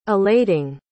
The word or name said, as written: elating